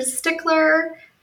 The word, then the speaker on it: Stickler: It's like the word Stickler. I am a stickler for the pronunciation of my name.